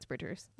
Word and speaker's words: Phoebe Bridgers: Phoebe Bridgers. Bridges? I don't even know. No, it's Bridgers. Let me get my facts straight. – Bridgers with a B? – B-R-I-D-G-E-R-S.